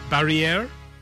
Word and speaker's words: Barrière: Barrière? Barrière. – Oh. Yeah, you've got to pronounce it with some gusto.